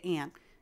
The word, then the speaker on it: Ayn: That's Kira A-Y-N Davis. Some people pronounce that Ayn, like Ayn Rand. And that's actually who I'm named after. But in my family, we say Ann.